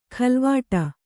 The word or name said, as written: ಖಲ್ವಾಟ